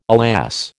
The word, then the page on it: alas: American English